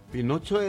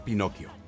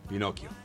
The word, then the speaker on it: Pinocchio: Pinocho es el personaje de una literatura italiana. La verdad que la pronunciación tendría que ser Pinocchio. Sí. Porque es italiano, Pinocchio.